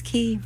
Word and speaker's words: quay: How are you going to do Q-U-A-Y and say Key? – It's Key.